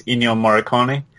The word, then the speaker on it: Ennio Morricone: It, like um, I believe how you pronounce his name is Ennio Morricone.